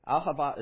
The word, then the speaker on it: Ahava: If you remember our ministry, Ahava, and you actually pronounced it correctly. most people can't, and it's Ahava is the Hebrew word for love.